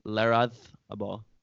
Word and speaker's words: Larrazábal: Pablo Larrazábal. I was told that's you pronounce it with the TH. – The Spanish pronunciation. TH.